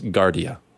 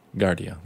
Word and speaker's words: Giardia: It's pronounced Giardia. Giardia. There you go.